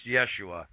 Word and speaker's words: Yeshua: However, at this time, his name was actually pronounced Yeshua.